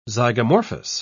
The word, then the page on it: zygomorphous: British English